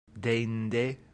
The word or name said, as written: deinde